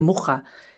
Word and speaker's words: Mucha: Muja. Se pronuncia Muja, pero se escribe Mucha.